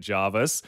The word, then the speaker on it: Jervis Bay: Your auntie texted you moments after that saying, can confirm it's Jarvis, 100% Jarvis.